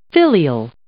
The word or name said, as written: filial